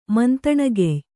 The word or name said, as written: ಮಂತಣಗೆಯ್